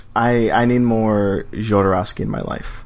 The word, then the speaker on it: Jodorowsky: Jodorowsky. – Jodorowsky.